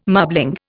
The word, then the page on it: marbling: Indian